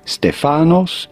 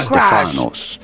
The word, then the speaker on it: Stefanos: Stefanos in Greek, you do want to stress on the fa syllable. – Holy cow, Stefanos.